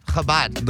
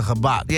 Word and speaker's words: Chabad: The store is on Fairfax, right next to the Habat. Is that how you say it? – Chabad. – The Chabad. – Yeah, Chabad, next door to it.